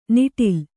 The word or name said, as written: ನಿಟಲ